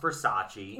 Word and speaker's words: Versace: She found out it's pronounced Versace.